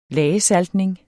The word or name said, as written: lagesaltning